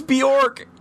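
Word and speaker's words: Björk: Did you just say Bajoric? – Bajoric? – I think we just had the funniest mispronunciation. Rhett, you have been dethroned. Because guess what? Segu is not the funniest anymore. Because we now have Sayara, who now holds the distinguished honor of having the funniest mispronunciation on this podcast with Bajork. – I'm hanging up now. – Her name's Bjork, you dip.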